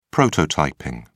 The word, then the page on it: prototyping: British English